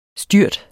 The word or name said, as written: styrt